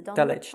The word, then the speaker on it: Dulwich: Lovecraft's stories was the Dulwich Horror. Or the Dulwich Horror. Or the Dulwich.